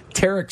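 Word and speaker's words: Tarik: I'm looking up the players, so I'm informed for the game when we have our advertising partners there that actually Tarek Skubal, I was pronouncing his first name wrong, Tarek Skubal is pretty good against the White Sox for his career, but the ERA a bit bloated.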